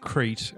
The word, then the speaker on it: Crete: It's pronounced Crete, and it's an island in Greece. He's saying it wrong.